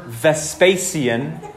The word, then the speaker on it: Vespasian: We have evidence historically that the Emperor Vespasian,